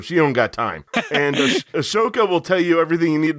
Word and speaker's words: Ahsoka: I'm kind of still hoping that, is it Ahsoka is how her name is pronounced? I never pronounce it right either. – Ahsoka. – Ahsoka.